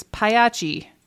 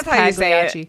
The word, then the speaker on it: Pagliacci: My ex-mother-in-law's maiden name was, it's Pagliacci. – Oh, really? – Yes, that's how you say it. But everyone says Pagliacci. – And I'm like, Pagliacci. – But I mean, every American says it Pagliacci, but whatever.